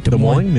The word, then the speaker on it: Des Moines: I just don't know why Des Moines, Iowa, is it, am I saying that right? – Des Moines? – Yeah, is above Fayetteville.